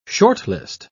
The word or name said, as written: shortlist